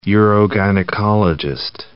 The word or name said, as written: urogynecologist